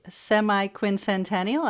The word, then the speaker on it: semi-quincentennial: And Sue Tran, July 4th, 2026 is our nation's semi-quincentennial. I think I said that correctly. – Good job. – Yeah, you did.